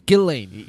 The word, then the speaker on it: Ghislaine: No, I'm sorry. It's Ghislaine. You're exactly. – Right. It's Ghislaine.